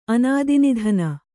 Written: ಅನಾದಿನಿಧನ

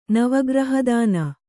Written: ನವಗ್ರಹದಾನ

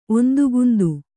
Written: ಒಂದುಗುಂದು

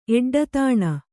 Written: ಎಡ್ಡತಾಣ

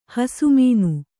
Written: ಹಸುಮೀನು

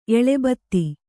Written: ಎಲೆಬತ್ತಿ

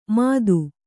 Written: ಮದು

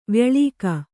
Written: ವ್ಯಳೀಕ

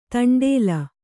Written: ದಂಡ್ಲೆ

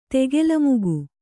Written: ತೆಗೆಲಮುಗು

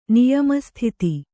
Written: ನಿಯಮಸ್ಥಿತಿ

